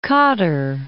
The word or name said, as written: Qatar